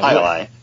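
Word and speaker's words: Jai Alai: I always say once I learned it was called Jai-Li, I call it Jai-Li. – Jai-Li. – Yeah. – Jai-Li. Like the game.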